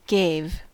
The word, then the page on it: gave: American English